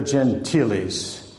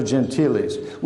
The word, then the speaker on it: Gentiles: Among them was the Summa Contra Gentiles, not pronounced the other way, the Summa Contra Gentiles, which was a missionary manual.